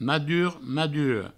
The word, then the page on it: madur, madura: provençal; madur, maduro